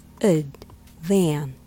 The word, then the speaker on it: advantage: The pronunciation of advantage is advantage. Let's break it down step by step. Syllable number one, ud. This is the unstressed syllable, pronounced with a schwa, a very light and delicate vowel sound, ud, ud. Syllable number two, van. This is the stressed syllable. Say it clearly with an open a sound, like in cat or van.